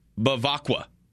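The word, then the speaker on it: Bevacqua: It was a lot of questions to Nick Saban, Notre Dame's athletic director, Bavaca. Is it Bavaca? Is that how you say it? – Blanca? – Pete Bavacqua. Bavacqua.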